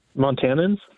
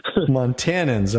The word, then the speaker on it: Montanans: Or Montanaites? How do you say it? – Montanans? – Montanans. Okay.